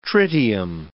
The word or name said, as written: tritium